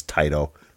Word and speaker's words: Taito: I do think the proper pronounce, pronunciation of that is Taito. – Yeah, it's a Japanese word, and it's a diphthong, like ta i toe. So Taito.